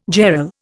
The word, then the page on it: jarl: Indian